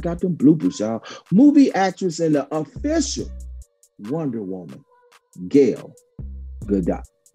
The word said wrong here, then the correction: Gal Gadot: Gal Gadot. Yes, I had to say it. I had to pronounce that. Gal Gadot.